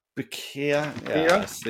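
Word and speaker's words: Kotobukiya: Koto Bukiya? – Boyoka? Yeah. – What is it? – Koto Boyoka? Bukiya? – Bukiya, yes, these guys.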